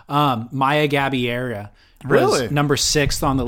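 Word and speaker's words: Gabeira: Gabiera. – Gabiera.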